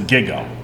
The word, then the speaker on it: GIGO: No, it's Gigo. – Got it going on. – Got it going on. – Yeah, so because got it going on, those two G's are hard G's, it's Gigo.